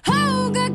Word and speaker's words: Cuyahoga: The summer heat and cross all the Cuyahoga, clean those dusty feet and get them black again all over, running out to meet the sunny sky.